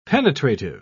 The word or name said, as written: penetrative